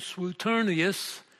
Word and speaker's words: Suetonius: An early Roman historian, Suetonius, it's S-U-E, but we pronounce it as though it was S-W, he wrote on the Caesars.